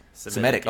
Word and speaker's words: Semitic: All of us are here writing in Arabic, Hebrew, Farsi, and other Semitic. How do you pronounce it? I'm so stupid. It's Semitic.